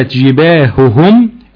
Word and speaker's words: جباههم: ونبه علينا في كلمتين، كلمة جباههم أن فيها هائين وراء بعض، وكلمة عليهم عليهم.